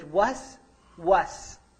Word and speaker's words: waswas: But the way the verb is in the Arabic language, waswas, it has two syllables and they're repeated.